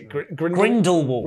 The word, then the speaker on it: Grindelwald: Dumbledore versus Grindelwald. Did I say it right? – Yeah, Grindelwald.